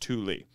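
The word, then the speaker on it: Tuli: First of all, the tag-along term is Thule, pronounced Thule.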